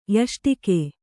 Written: ಯಷ್ಟಿಕೆ

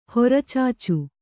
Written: ಹೊರಚಾಚು

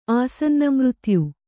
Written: ಆಸನ್ನಮೃತ್ಯು

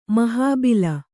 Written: ಮಹಾಬಿಲ

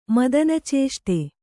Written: ಮದನಚೇಷ್ಟೆ

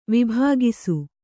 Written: ವಿಭಾಗಿಸು

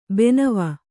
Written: ಭಾನವ

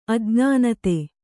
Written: ಅಜ್ಞಾನತೆ

